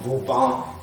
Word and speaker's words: Vauban: mit 6 Buchstaben, der heißt V-Bahn, ausgesprochen Vauban, die vaubanischen Befestigungsanlagen, wie sie in Gesamteuropa nachgebildet wurden.